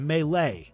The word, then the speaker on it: Melee: And Nice Guy, tell me, why is Melee so beloved? – You know, I don't know, and I've never played Super Smash Bros. Melee. I have played Super Smash Bros. Melee, which is how it's pronounced.